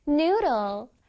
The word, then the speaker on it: noodle: Noodle. Noodle. N-O-O 부분을 길게 발음해 줄 거야.